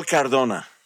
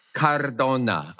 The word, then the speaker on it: Cardona: Miguel Cardona. – Cardona. Okay. All right. – You don't have to roll the R because it's only one R.